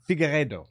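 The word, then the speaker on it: Figueiredo: Figueredo. No, Figueredo. Yeah, that was pretty good. I, oh, ah, and I was I knew I, I was pronouncing it. The do comes at the end, Figueredo, which means fig orchard.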